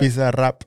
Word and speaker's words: Bizarrap: Is that how you say it? Bizarrap? – Bizarrap. – Bizarrap. – Yeah.